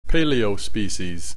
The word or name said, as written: paleospecies